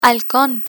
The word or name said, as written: halcon